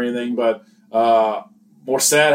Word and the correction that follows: Mostert: had a great – not Morissette. I'm saying that wrong. – Mostert. – I can't read, I swear. Mostert.